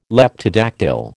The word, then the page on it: Leptodactyl: American English